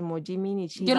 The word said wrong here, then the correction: Momiji Nishiya: Yo lo puedo decir. Sí, ay, perfecto, por favor. Momiji Nishiya y Raiza Leal.